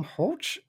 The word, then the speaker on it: Hulce: Stars Tom Hulce. At least that's how the internet says I should pronounce his last name. I had no idea.